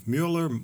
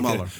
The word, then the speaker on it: Mueller: Mueller is, how do you say his name? Mueller. – Okay. Mueller. Yeah.